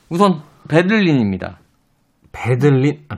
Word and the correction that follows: 베를린: 아, 베를린. 네. 베들린이라고 하시나 아니죠? 베를린. 베를린.